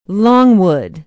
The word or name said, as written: longwood